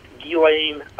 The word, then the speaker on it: Ghislaine: Television says a million different things, but it's pronounced Ghislaine Maxwell.